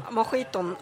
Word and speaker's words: mojito: Nu uttalar jag det på, på vad jag tror är spanska. Spritmojito. – Mojiton. – Mojito. Ja.